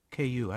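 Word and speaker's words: Ku (酷): In Mandarin's K-U. How do you pronounce that? – Ku. – Ku. Okay. Just like Bill and Ku.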